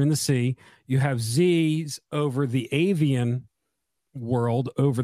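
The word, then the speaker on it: Ziz: It's actually Z's, Z-I-Z, pronounced Z-I-Y-Z.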